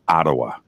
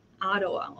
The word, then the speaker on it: Ottawa: But it is Ottawa. – Ottawa.